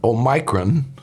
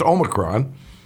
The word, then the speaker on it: omicron: And while we are in the midst of yet another COVID variant, this one called Omicron, or as I used to call it, Omicron, until I heard it's pronounced Omicron, President Biden's approval rating slipping to 36%.